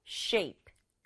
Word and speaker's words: shape: sh가 만나면 sh 소리 나고요. a 다음에 e가 따라오기 때문에 a가 자기 이름처럼 a 하고 소리 나요. shape, e는 소리 나지 않는다. 그래서 한 박자로 shape, shape 이렇게 발음해 주세요.